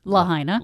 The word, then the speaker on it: Lahaina: Lahaina.